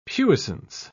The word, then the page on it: puissance: British English